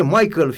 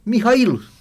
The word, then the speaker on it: Mihail: Unii spun fie Michael, fie Michel, sau alții preferă să se refere la el după prenumele Neofitos, când de fapt este Mihail.